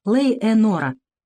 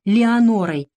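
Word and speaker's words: Леонора: Шоу расшифровывает звучание имени вердиевской героини через английскую фразу «Лей Энора». «Я умоляю их впредь называть ее Леонорой, как окрестил свою героиню сэр Джеймс Барри. По крайней мере, это звучит по-английски. А «Лей Энора» — это просто дурное произношение».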